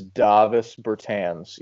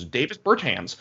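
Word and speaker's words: Dāvis Bertāns: I believe it is Davis Bertans. Yes. – Davis not Bertans. Davis. I said it as white as possible. It's Davis Bertans.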